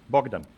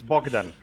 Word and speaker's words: Bogdan: Bogdan. It's just like your friend, from Romania. It's Bogdan. Come on.